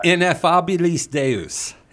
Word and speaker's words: Ineffabilis Deus: And they were talking about, I'm going to pronounce this wrong, so you'll have to say it correctly. – Okay. – But Pope Pius IX's constitution, ineffabilis Deus. – Yes, it's ineffabilis Deus.